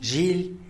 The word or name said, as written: zhily